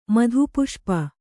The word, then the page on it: ಮಧುಪುಷ್ಪ: madhu puṣpa